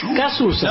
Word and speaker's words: Cazuza: Ah, Cachucha, conozco. – El trío Piedachi. – ¿De quién? – Cachucha. Cachucha. – Cazuza. – No, pero se pronuncia más absorbiendo. – Cachucha. – No, absorbiendo las vocales, ¿no? – Cazuza.